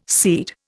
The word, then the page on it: seed: Indian